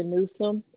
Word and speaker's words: Newsome: Sandra Newsom, N-E-W-S-O-M-E. – And that was Sandra, S-A-N-D-R-A? – Yes.